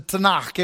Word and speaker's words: Tanakh: They called it the Tanakh. If you say it, you gotta, you know, kind of have that sound at the end, like you're clearing your throat. It's the Tanakh.